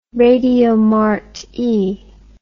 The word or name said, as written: radiomarti